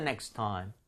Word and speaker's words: next time: I'll do that next time. 이렇게 아무 전치사 없이 사용하시고 이거 발음 한번 해 주실래요? 연음 좀 처리해 주세요. – TT가 들어가 있기 때문에 한 번만 발음하시면 됩니다. Next time.